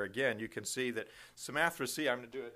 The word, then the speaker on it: Samothracia: We came with a straight course to Samothracia, and there's several different ways to pronounce that. There are some, and it's often pronounced with a K sound in some different readings. They have the K sound in there.